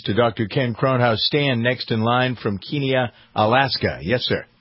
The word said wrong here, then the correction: Kenai: Kenai. – Yes. – Kenai. I always got to pronounce it correctly.